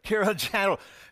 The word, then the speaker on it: Kilimanjaro: Kilimanjaro.